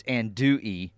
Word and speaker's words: andouille: Learn to say things. It's Andouille, which right now I'm clicking on this guy and blocking him from the text line. So. – Andouille? You don't pronounce the L? – Apparently not.